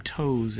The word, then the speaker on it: Toews: And your second novel that you want to talk about is Women Talking with Miriam Toews as the author. That's how we're pronouncing her name. Her name is spelled T-O-E-W-S. We hope we're pronouncing that correctly.